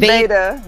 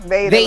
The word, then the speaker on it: VADA: It's VADA. VADA. Like, mm-hmm. I knew I was going to mess it up. – No, you're fine. I always say VADA like tomato.